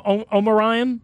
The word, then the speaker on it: Omarion: Omarion.